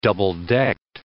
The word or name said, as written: double-decked